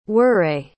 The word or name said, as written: warre